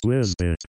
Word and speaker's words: WZBT: WZBT, pronounced Wizbit.